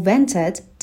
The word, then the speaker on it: vent: به تلفظ کلمه vent هم دقت داریم صدای V رو داریم در ابتدا صدای W نداریم اینجا دندون‌های بالا لب پایین رو لمس می‌کنه یک بار دیگه بخونیم